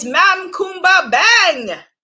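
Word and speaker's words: Mame Coumba Bang: Next up is Mam Kumba Bang. It's spelled like Mame, but it's pronounced Mam Kumba Bang.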